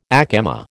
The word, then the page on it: ack emma: American English